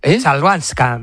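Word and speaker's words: Chwalińska: Joder, ¿cómo se dice la es complicada, ¿eh? Chabalinska. – Chalbanska. – Maya Chalbanska.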